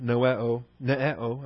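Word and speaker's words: ananeo (ἀνανεόω): There's also another term for renewal, ananeo. A-N-A-N-E-O-O. That's the short O, Omicron, long O, Omega.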